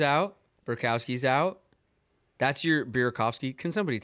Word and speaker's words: Burakovsky: It's Burakovsky.